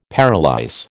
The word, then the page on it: paralyse: American English